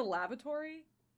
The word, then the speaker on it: lavatory: It's, it's lavatory. There's no lavatory. – Yeah, lavatory. Jesus Christ. I was like, where are you getting the vor from?